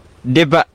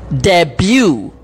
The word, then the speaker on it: debut: Ini dia cuba perkataan D-E-B-U-T. – Debut. – Boleh duit tak? – Debut. – Yang last yang betul itu suara cikgu sebenarnya. Kata debut. – Debut? – Uh-uh. – Oh, bukan debat.